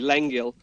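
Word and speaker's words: Lengyel: And the guy playing who plays Michael Curtiz, he's a Hungarian actor called called Ferenc Jengel. – Okay. – Sorry, Lengel. I hope I'm close to pronouncing that correctly.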